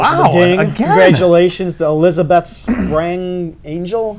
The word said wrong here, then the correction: Sprangle: Am I saying that right? No. – Just how the Sprangle.